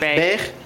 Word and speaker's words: Beghe: B-E-G-H-E. How do you pronounce that? – Beige.